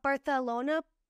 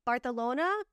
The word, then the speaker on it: Barcelona: There was a clip that was circulating a couple weeks ago from the Beverly Hills After Show of Dorit being like, Barcelona, Barcelona. Like, she, like, said Barcelona and then corrected herself. She's like, I mean, Barcelona. Like, she had to say it like an authentic local, you know, Spanish person.